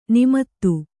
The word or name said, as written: ನೇಮಿತ